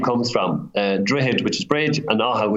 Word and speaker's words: Drogheda: Drogheda, believe it or not, the G is silent. I understand why you'd want to focus in on the G, but it's silent.